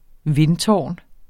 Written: vindtårn

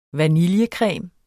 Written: vaniljecreme

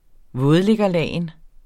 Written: vådliggerlagen

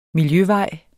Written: miljøvej

